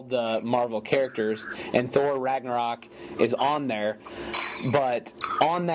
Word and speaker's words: Ragnarok: Yeah, Ragnarok.